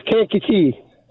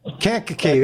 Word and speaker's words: Kankakee: Hey, was I close in the pronunciation? – Close, Kankakee. – Kankakee, there you go.